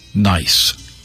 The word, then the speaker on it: gneiss: Gneiss is spelled G-N-E-I-S-S, but is pronounced nice, and white streaks of quartz and feldspar.